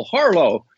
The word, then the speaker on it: Harlow: The media in Washington descended on CIA headquarters and asked this fellow, Bill Harlow, who uh, I remember his last name easily because it rhymes with harlot, okay?